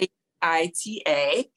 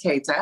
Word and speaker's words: Keita: it K I T A and I pronounce it Kita, even though it's really pronounced Keita.